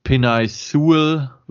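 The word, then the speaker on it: Penei Sewell: Auch wenn sich äh, Pinay Sewell, wird das, wird er, glaube ich, ausgesprochen, also der Tackle, den die Lions gedraftet haben, wirklich gut geschlagen hat gegen die 49ers.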